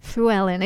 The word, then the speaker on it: Llewellyn: So it's L-L-E-W, like Llewellyn, but the correct pronunciation is Welsh. It's Llewellyn.